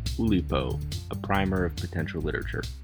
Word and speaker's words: Oulipo: It's well known within literary circles, and pardon my pronunciation, Oulipo. – Your pronunciation is absolutely correct. It is Oulipo.